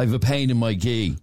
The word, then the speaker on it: ghee: G-double-E. Your ghee bag. – Oh, yeah. – That's pronounced ghee.